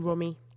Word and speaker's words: Rumi: Rumi.